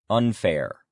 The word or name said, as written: unfair